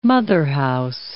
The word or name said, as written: motherhouse